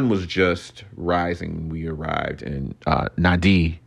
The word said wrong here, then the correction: Nadi: Nandi, not Nan, not Nadi, Nandi. It's spelled N A D I, so that's why I said Nadi but it's Nandi.